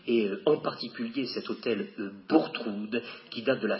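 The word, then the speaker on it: Bourgtheroulde: Méfiez-vous de l'orthographe, hein, mais la prononciation, c'est bien Bourtroude. On prononce Bourtroude.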